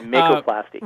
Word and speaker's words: Makoplasty: Now, the surgery that you had to replace your hip was called a makoplasty. Am I pronouncing that correctly? – That's correct, makoplasty.